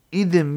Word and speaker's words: idim: The word idim, we're pronouncing it that way because that's the way the Mefarshim spell it, is idim means a cloth, obscure word for a cloth.